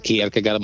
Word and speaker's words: Kierkegaard: che è Kierkegaard, giusto? Più o meno la pronuncia l'ho indovinata. – Sì, Kierkegaard, ma poi in tedesco sarebbe, ma comunque ci siamo.